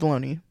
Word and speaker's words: bologna: I find those, I've never had bologna, but I feel like if I ate bologna, I would have olive bologna, because that's like the most depressed you can get. – Yeah, bologna, the G is silent.